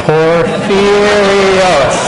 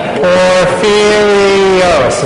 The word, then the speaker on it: Porphyrios: Ready? No. Porphyrios. Now, we can, we can all get this. Ready? Porphyrios is four syllables. I won't have you say Kovsakalivia. Just Porphyrios.